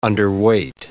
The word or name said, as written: underweight